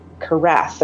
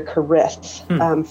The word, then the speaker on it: Kerith: But there are male Keriths, and some of them pronounce their name Kerath or Kerith. For me, it's Kerith.